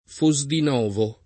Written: Fosdinovo

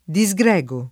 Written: disgregare

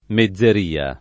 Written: mezzeria

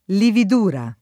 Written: lividura

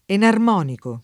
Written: enarmonico

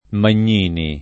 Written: Magnini

